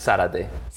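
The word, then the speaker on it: Zárate: Yeah, well, in Spanish, it's Zarate. – Yeah, that's why I'm saying it. Like, do you pronounce the Z? Do you not? It's like English or Spanish. Whoever's saying it, then it changes.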